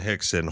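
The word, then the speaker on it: Rickson: Hoist, Hickson. Yeah, I mean, like there are other Brazilian names that start with R, pronounced H, but they're names you would recognize, like Rogers, like Roger, you know.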